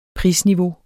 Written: prisniveau

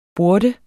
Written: burde

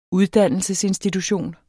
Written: uddannelsesinstitution

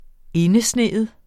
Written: indesneet

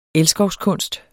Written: elskovskunst

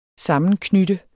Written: sammenknytte

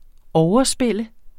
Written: overspil

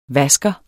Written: vasker

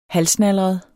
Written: halvsnalret

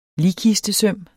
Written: ligkistesøm

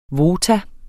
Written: VOTA

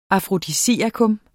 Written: afrodisiakum

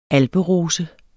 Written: alperose

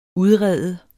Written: udrede

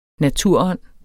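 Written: naturånd

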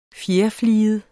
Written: fjerfliget

